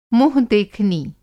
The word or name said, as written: मुँह-देखनी